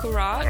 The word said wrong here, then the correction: curhat: Nope. It's pronounced curhat. It's an Indonesian term.